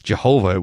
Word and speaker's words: Jehovah: Yahweh, we sometimes pronounce it. The old Jehovah, it was sometimes pronounced in the old days, but that name is indicated by the, in English, by the all capital letters.